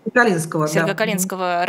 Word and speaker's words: Сергокалинского: А вот по поводу, собственно, теракта, который вызвал в том числе высказывание Рамзана Кадырова, стало известно, что шестерых сыновей бывшего главы Сергокалинского, я правильно ставлю ударение? – Сергокалинского района Дагестана, их арестовали по обвинению в мелком хулиганстве.